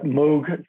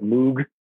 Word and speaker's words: Moog: It's like Moog synthesizer instead of Moog. Like we want to say Moog, but everyone I know in music and in the Netherlands says Moog. So I have to presume that's the correct pronunciation.